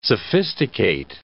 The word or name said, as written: sophisticate